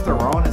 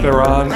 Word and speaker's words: Theron: Is it Charlize Theron? Is that how you say her name? – Theron.